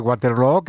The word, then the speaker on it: Waterloo: Desde luego, pero ABBA ha hecho mucho daño en la pronunciación de Waterloo, que se pronuncia igual que Feijóo.